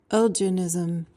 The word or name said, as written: elginism